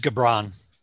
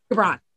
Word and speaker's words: Gibran: So, It is Gibran. – Gibran, thank you.